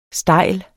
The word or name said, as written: stejl